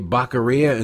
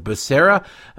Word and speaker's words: Becerra: Why did he say Baqueria instead of Becerra? Because it's spelled Baqueria if you don't notice that the first name is Javier.